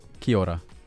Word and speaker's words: Kia ora: Kia ora. – So it's like A is basically silent. It's like kia ora. – Yeah. – Okay. – Yeah. Phonetically sounding. – Like Rita Ora, but New Zealandish.